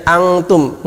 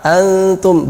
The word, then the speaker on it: antum: Ini bukan bacanya angtum, bukan n, banyak yang baca dengan ng, sehingga dia malah persiapan kaf. Harusnya persiapan ta, antum, bukan angtum.